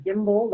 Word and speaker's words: Gimbel: And my last name is Gimbel, like the department store that went out of business, G I M B E L.